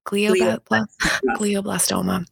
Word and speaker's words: glioblastoma: It's glioblastoma.